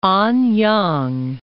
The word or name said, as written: Anyang